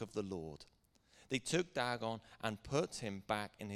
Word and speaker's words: Dagon: Then they carried the ark into Dagon's temple and set it before Dagon. Am I pronouncing that right? That'll do.